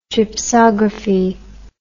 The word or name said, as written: gypsography